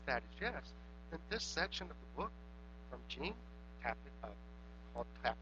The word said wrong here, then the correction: Jen: Into Mother Love, might be right up your alley because she talks about, I said her name wrong, it's Jen, not Jean.